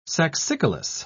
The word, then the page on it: saxicolous: British English